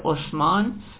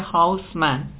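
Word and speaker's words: Haussmann: نامش تلفظ فرانسویش میشه عثمان، تلفظ آلمانیش چون منطقه بین آلمان و فرانسه است تلفظ آلمانیش میشه هاوسمن، ولی در کتاب‌های فارسی هاوسمان نوشتن و ما هم هاوسمان مینویسیم.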